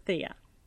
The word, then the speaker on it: Theia: Some people might pronounce it Thea, but I pronounce it Thea.